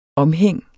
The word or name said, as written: omhæng